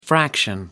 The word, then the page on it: fraction: American English